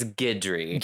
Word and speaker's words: Guidry: It's Guidry. – Guid. Okay, so we just, the U. – The U is silent.